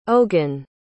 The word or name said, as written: ogin